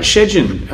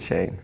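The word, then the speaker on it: Szejn: What about Shejin? I think that's how you pronounce his name. – Shane. – Shane. It's not Shane. – It's Shane. In the Polish way, it's Shane.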